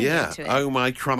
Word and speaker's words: Omicron: Well, uh, well, apparently due to the Greek alphabet, the experts are saying that Omicron, the emphasis on the my, is apparently the right oh.